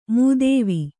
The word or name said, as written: ಮೂದೇವಿ